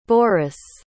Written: boris

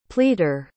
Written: pleater

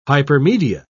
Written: hypermedia

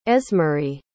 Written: esmerie

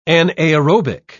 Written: anaerobic

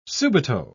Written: subito

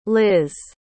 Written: liz

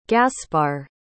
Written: gaspar

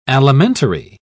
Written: alimentary

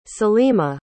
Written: saleema

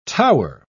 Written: tower

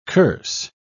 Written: curse

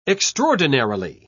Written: extraordinarily

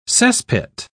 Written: cesspit

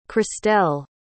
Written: cristelle

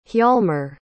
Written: hjalmer

